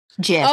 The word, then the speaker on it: GIF: Because now I'm looking at the Dean gifs. – Oh, right. You said that like that on purpose. It's a GIF. It stands for graphic. It's a hard G.